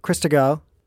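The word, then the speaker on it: Christgau: Robert Krisko, finally say that name right.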